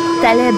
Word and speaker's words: taleb: Le talb, on dit Taleb, ça s'écrit Taleb.